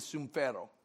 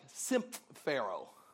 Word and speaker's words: sumphero: This word in the Greek is sumphero. If I were to say it like you would recognize an English word, it would be like symphero. – sounds a little bit like the word symphony. Symphony.